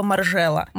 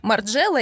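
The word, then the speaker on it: Margiela: Да, это мы на днях спорили с моей одной приятельницей, как правильно, неправильно произносить слово «маржела». Маржела или маржела? Потому что вообще он маржела. Он маржела. Мезон Мартан маржела. Oh потому что французский, бельгийский, вот это вот все.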